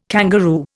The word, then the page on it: kangaroo: Indian